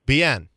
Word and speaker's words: Bien: Okay, Bien.